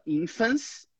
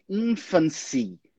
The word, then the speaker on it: infancy: Às vezes o aluno vai lá e fala infants, não é. É infancy.